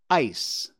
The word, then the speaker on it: ais: What does the word A-I-S mean? A-I-S. How would you pronounce that? Ice. A-I-S. Ice. Well, guess what? That's the Malay word for ice. A-I-S.